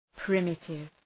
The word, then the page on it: primitive: ‘pri:mıtıv